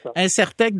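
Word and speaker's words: Insertech: Incertec, donc ça s'écrit I-N-S-E-R-T-E-C-H. I-N-S-E-R-T-E-C-H.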